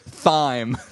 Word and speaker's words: thyme: I've decided not to give you thyme. – Thyme. – Thyme. Like thymus.